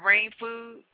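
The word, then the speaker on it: Reign Food: So I understand you have a project out called Steamulus, the Watts Riot Effect, and you have Rain Food, and that's spelled R-E-I-G-N.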